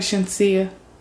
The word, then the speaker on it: Shenseea: I know he did a song with um, Shenseea. Is it Shenseea? Is it? Am I saying it right? Shenseea.